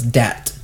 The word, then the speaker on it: Dhat: And she spelled that D-H-A-T. – So it's dat. – Dat. So the H is silent.